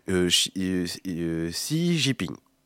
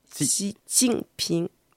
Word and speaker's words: Xi Jinping: Moi, j'aurais tendance à dire Xi Jinping. – Xi, c'est bien. – Ouais. – Et Jinping. – Donc, Xi Jinping. – Ok. – Voilà. Bon, je pense que ce que tu as prononcé, c'est déjà très bien.